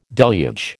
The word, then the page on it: deluge: American English